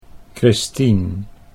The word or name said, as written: Christien